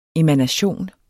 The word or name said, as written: emanation